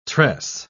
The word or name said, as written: tress